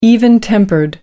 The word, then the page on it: even-tempered: American English